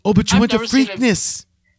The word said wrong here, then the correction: Freaknik: It's Freaknik, not Freakness, which is a thank horse you.